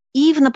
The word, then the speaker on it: Ивна: Ивна, потому что «ю» читалось, произносилось как «в».